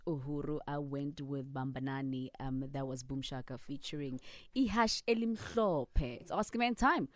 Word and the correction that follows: Ihashi Elimhlophe: 089, what did I say it right? – Ihash. Ihashi Elimhlophe.